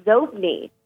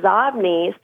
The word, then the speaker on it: Xobni: if I said X-O-B-N-I, or all the listeners, but yeah, and get this, it was pronounced Zobni when they first started the company, and Bill Gates pronounced it Zobni, so they changed the pronunciation based on how Bill Gates pronounced it.